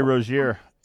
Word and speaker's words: Rozier: They're falling apart because they lost that Rozier, or how do you say his name? He signed away earlier. – Terry Rozier, yeah.